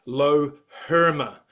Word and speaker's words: Loherma: Yeah, no, look, it's actually not Spanish. It's I believe it's German or East or Western European. So it's Loherma.